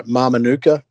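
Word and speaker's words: Mamanuca: We're in those, you know, I don't know if I pronounce this right, Mamanuka group. – Yeah, you nailed it. I just checked. You killed it.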